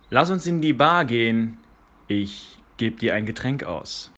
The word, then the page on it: ein Getränk ausgeben: German